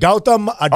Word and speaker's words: Gautam Adani: G-A-U. You know what? Gautam Adani.